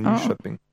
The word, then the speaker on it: Nyköping: Damals dachte mir noch, es heißt Nyköping, aber K-Ö wird schö ausgesprochen. Nyköping. So wie Köttbullar, nicht Köttbullar beim IKEA.